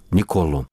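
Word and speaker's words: Niccolò: И во Флоренции ударение на последний слог. Николо.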